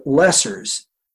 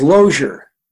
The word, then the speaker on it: Loesser: and of course, I called it Lesser's. But I realized one day, talking to someone, it was Lozier. That was how everybody said it, because that's probably the German pronunciation.